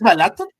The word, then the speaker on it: Balaton: Si no me equivoco, la extracción de petróleo está muy cerca del lago, ¿es Balaton? Sí, ¿verdad? – Eh, me parece que es con acento en la O, entonces eh, sí, y de hecho sí tiene razón.